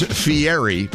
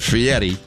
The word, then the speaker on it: Fieri: Now, it is Fieri, even though he pronounces it Fieri.